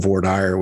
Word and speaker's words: voir dire: And then another big thing that happens at the jury selection stage, or what we in Texas call voir dire, which is, for our non-Texas listeners, is a major mispronunciation of the original French phrase, but it's sort of in our nomenclature.